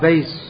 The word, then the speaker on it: bass: The bass, it's called bass in English. Bass is a kind of fish, spelled the same way, but pronounced differently.